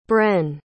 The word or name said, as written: bren